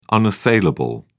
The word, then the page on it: unassailable: British English